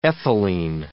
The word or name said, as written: ethylene